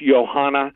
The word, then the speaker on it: Joanna: Now, the challenger and former champion, the Polish fighter, it looks like it's Joanna, but it's pronounced Johanna Jędrzejczyk.